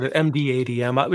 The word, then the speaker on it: mdadm: Now, now that we mentioned that, I think we talked a little about MDDM. I'm saying it wrong. – It's so hard. MDADM. We can just say MDRAID.